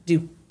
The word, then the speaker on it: Dieux: My speaking of do, is that how you say it? Dukes? – Yeah. – Do. D-I-E-U-X. Do.